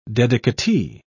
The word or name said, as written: dedicatee